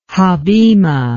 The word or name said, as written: habima